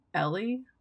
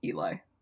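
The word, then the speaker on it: Ely: And I always pronounced it in my head Ellie? – I think that's Eli, how it's pronounced. Eli? It's E-L-Y.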